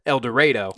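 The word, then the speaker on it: Eldorado: The heart of Egypt is the towns of El Dorado, that's spelled like El Dorado, but I am assured that it is pronounced El Dorado.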